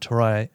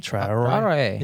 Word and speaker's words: Traoré: And that's, like, this new guy, Ibrahim Touré, however you pronounce his name. – Yeah, Traoré? – Traoré.